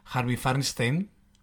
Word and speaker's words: Harvey Fierstein: Pues que Divine era una madre amantísima, maravillosa, que luego llegó a los escenarios encarnada por Harvey Fierstein, ¿no? ¿Lo he dicho bien el nombre? – Sí. – Bueno, correcto. Más o menos.